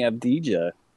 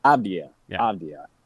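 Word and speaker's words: Avdija: I think Denny Abdija, if I'm saying that right. – Nope, but that's okay. – can we get an update? Abdija. If we can get that right.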